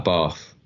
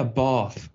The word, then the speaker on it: Bath: Bath. Why did I say it like that? Jeez. – Like us. – We convert you. You're going to become English soon. – Oh, yeah, yeah. Bath.